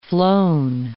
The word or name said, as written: flown